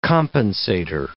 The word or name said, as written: compensator